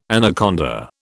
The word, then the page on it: anaconda: British English